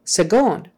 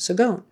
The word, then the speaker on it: seconde: And I'm like, why are they pronouncing that with a G? It's spelled with a C. And then I finally Googled it, and sure enough, that's that's just what you do. You pronounce it with a with a G sound, seconde.